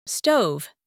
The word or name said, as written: stove